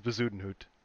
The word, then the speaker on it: Bezuidenhout: That's not how you pronounce that shit. – Yeah, it is, Bezuidenhout. Christian Bezuidenhout.